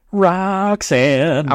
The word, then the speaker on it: Roxanne: I'm sorry, could you pronounce the name of this card again? – Roxanne. – I'm glad you got that right away.